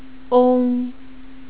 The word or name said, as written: ong